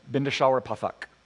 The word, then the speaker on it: Bindeshwar Pathak: Let me tell you about this guy named um, Bindawar. Let me get his name properly. Wait for it. there it is. Bindeshwar Pathak